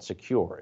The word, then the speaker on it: Sekur: That's why you need a new privacy and cybersecurity tool called Secure. It's spelled S-E-K-U-R.